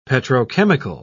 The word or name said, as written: petrochemical